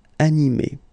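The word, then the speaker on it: animée: But then the thing is that you don't pronounce it. So phonetically, you will get exactly the same form. So masculine form, animé, and then a feminine form, animé. Okay, you don't pronounce this e, but then you should write it.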